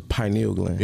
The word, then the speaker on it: pineal gland: It's the pineal gland.